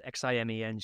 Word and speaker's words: Ximen: Just for the benefit of people listening, so Ximen in the anglicized alphabet is Ximen, right? Ximen is pronunciation, but that's how it's spelled. – Yes, Ximen, Ximen.